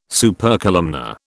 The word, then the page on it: Supercolumnar: British English